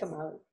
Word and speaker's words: Umali: Yes, Umali.